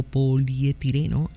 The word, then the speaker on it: polietileno: me parece que se dice poliutireno o polietileno, algo así. – Polietileno se dice.